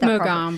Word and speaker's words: Mogambo: yeah, it's Mogambo.